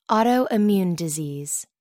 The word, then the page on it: autoimmune disease: American English